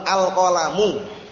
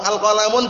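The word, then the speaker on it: al-qalamu (القلمُ): Kitabun, jidarun, tapi kalau ada al, antum bacanya gak perlu ragu-ragu, al-qalamu, paham belum? Kalau al-qalamun benar atau keliru, al-qalamun, gak boleh.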